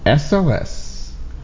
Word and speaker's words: SOS: The song is titled SOS, but your screen reader will pronounce it SOS. But it's actually just the three letters pronounced as they are. S-O-S.